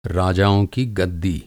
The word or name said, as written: गद्दी